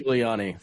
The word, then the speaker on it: Giuliani: But the scene with, I'm going to get his name wrong, Rudy Giuliani. Is that his name? Or Giuliani? – Rudy Giuliani.